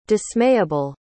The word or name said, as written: dismayable